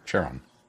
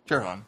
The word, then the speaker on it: Charon: And I can never pronounce it right, so I'm going to skip it. Charon? – Charon. – Charon. Yeah, I did it right.